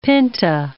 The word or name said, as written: pinta